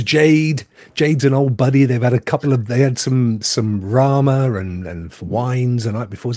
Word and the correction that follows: ramen: Is that what you pronounce it? Rama? What's that? The noodles? – Yeah, ramen.